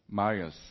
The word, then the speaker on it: Marius: Through the lives of Marius, am I correct to pronounce, Penico? Marius or Marius? Marius, okay.